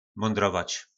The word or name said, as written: mondrovac